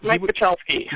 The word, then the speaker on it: Michalski: I mean, Michalski, is that how you pronounce it? – Yeah, Mike Michalski.